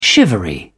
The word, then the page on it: shivery: British English